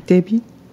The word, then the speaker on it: debut: You don't pronounce the T. Listen to debut.